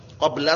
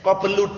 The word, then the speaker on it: qabla: antum bacanya jangan qablu jangan ba'du qabla sama ba'da. Faham? itu perlu diperhatikan baik-baik. Qabla darsi, qablu darsi enggak boleh. Ba'du darsi enggak boleh. Untuk saat ini enggak boleh ya. Jadi ba'da qabla.